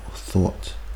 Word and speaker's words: Thoth: This is a very famous one by Hermes, otherwise known as Thoth in the ancient Egyptian world, or thought, pronounced like thought in the brain, thoughts.